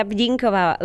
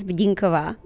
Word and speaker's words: Bdinková: My name is Markéta Bdinková. Last name is Bdinková.